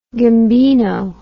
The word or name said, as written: gambino